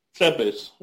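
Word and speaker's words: Țepeș: Tepes.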